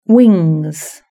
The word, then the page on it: wings: British English